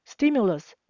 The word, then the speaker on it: stimulus: Stimulus 本身是指刺激，有刺激消费的意思。三个音节，stimulus 这边的 t 念成 d 会比较好念。